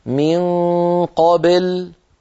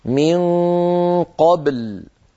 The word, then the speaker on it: قبل: الخطأ الأول أن تميل الباء إلى الكسر فيقول أحدنا من قابل، قابل هذه بل لا تصح، لابد أن تنطق الباء ساكنة مقلقلة هكذا من قبل، قبل.